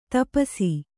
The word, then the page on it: ತಪಸಿ: Kannada